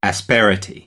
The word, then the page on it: asperity: American English; ɾ